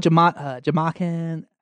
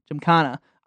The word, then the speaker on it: gymkhana: Jamakin? How you say it? – Gymkhana.